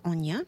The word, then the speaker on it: Enya: And we heard just then Watermark from Enya, or Enya. I believe the pronunciation is Enya.